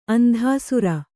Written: ಅಂತಃಸಾರ

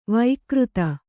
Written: ವಕ್ರಿತ

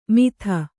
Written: ಮಿಥ

